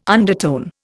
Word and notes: undertone: Indian

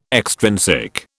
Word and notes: extrinsic: British English

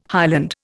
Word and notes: highland: Indian

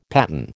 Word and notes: paten: American English

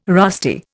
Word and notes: rusty: Indian

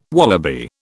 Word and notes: wallaby: British English

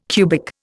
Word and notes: cubic: Indian

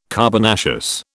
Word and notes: carbonaceous: British English

